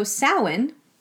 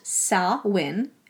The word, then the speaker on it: Samhain: So, Samhain, pronounced Sawin. it looks like Samhain, but it's pronounced Sawin.